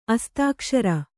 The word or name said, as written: ಅಸ್ತಾಕ್ಷರ